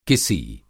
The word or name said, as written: किसी